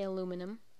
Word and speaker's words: aluminum: It's spelled aluminium, even though we say aluminum.